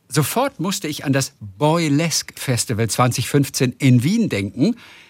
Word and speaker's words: Boylesque: Bo äh, das Boy, Boy, wie Boy und Girl. – Ja. – Und dann Lesk mit QUE hinten. – Ah, Boylesque. wie Burlesque. Okay. – Burlesque, nur okay. eben Boylesque.